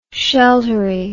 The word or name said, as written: sheltery